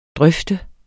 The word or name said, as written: drøfte